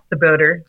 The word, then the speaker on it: Czebotar: Close, yeah, Czebotar.